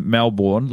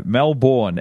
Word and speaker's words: Melbourne: That's how we know he's not from there, folks, because he doesn't pronounce it M-E-L-B-I-N, which every local does. – No, Melbourne.